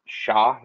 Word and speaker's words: Shah: Shah, is that how you pronounce it? – Yeah. – What did I say? – Didn't you say Sean? – Same thing.